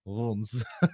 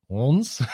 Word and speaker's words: Reims: Apparently, you pronounce the R at the back of the throat. It sounds a bit like France said in with a French accent, just without the horns.